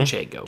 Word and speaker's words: Manchego: Manchego.